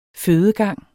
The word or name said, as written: fødegang